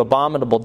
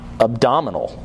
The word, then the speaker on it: abominable: They do abominable deeds. Abominable. Make sure I pronounce that correctly. Not abdominal.